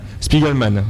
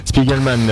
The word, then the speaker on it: Spiegelman: alors je vais mal le prononcer, Spiegelman, l'auteur de Mouse, les petites souris, Spiegelman, merci, Spiegelman, Mouse.